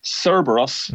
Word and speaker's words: Cerberus: But I think if Joseph sends Cerberus, that's the correct pronunciation of that one, his form stacks up pretty well against this.